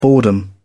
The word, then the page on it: boredom: British English